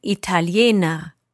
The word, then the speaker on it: Italiener: Ich bin Italiener. – Italiener, with an R at the end? – Genau.